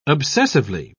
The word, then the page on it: obsessively: British English